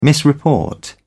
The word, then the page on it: misreport: British English